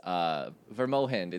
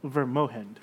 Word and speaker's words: Vermogend: On the way, you can look at the map and see the town's name is Vermohind. It's Vermogend, but the G is pronounced with an H. – Okay. Vermohind.